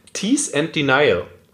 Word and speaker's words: Tease and Denial: Da habe ich einen Experten, lustigerweise am Start, extra zu, der, zu, der, zu dem Thema oder zu dem Tease and Denial. – Habe ich das richtig ausgesprochen? Ja, ja ne? hast du.